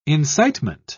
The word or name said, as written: incitement